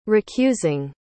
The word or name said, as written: recusing